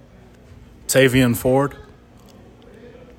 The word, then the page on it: Tavien Ford: TAY vee ihn FORD